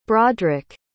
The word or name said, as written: broderic